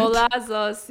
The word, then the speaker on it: Golazos: So I know you're working on a project that you renamed, I think, to Golazos. Do I say that right? – Golazos, yeah. So in Spanish, golazo is like a spectacular goal someone made.